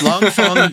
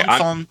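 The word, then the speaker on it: Leng Feng: I think it's Fong. – God damn it. Let me keep going. – Yeah, it's pronounced Long Fong, played by Wu Jing.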